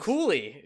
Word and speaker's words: Cooley: Cooley is how you pronounce that name, apparently. – I thought it was Ghoulie. – Is it Ghoulie or Cooley? – Yeah, with a G. Is it with a C or a G? – I think it's with a C.